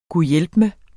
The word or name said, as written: gud